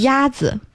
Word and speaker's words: 鸭子: 鸭子.